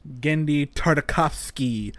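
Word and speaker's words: Genndy Tartakovsky: Gendy Tartakovsky? – Gendy Tartakovsky, the man, the myth, the legend.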